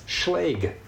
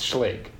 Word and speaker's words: Schlage: It's Schlage. – Hmm. – There is a video on their website saying, yeah, we've heard people pronounce our name a lot of different ways. It's Schlage.